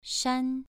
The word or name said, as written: shan2